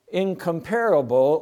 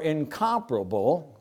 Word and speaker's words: incomparable: So what is incomparable or incomparable? Both of those pronunciations are correct. I had to look that up because I've used the word. Anyway, they're both correct.